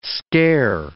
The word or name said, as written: scare